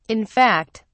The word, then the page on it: in fact: British English